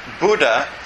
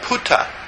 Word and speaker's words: Buddha: If you say Buddha, for instance, in Mahanikaya, in Thailand, it's called Putta, P. If you say Dhamma, it's called, pronounced Tamma. This is not correct.